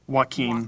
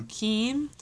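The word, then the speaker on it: Joachim: Joaquim. – Joaquim.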